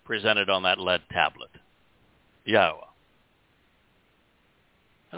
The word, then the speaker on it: Yahowah: If you can say Torah, you can pronounce Yahowah. If you know how to pronounce the Hebrew verb that means to be, to exist, Hayah, you can pronounce Yahowah.